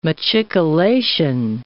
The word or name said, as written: machicolation